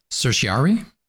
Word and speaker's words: certiorari: Certiorari? – Yeah, that one.